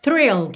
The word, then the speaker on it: thrill: Fique aí atento, atenta às diferenças de pronúncia de fill, que é com F mesmo, e thrill, que começa com TH, th, linguinha lá na frente, lá nos dentes da frente, e tem R, thrill, ok?